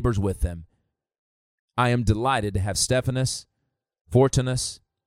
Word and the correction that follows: Fortunatus: or Fortunatus, uh, and, Achaicus uh, present, because these men have easy names to pronounce.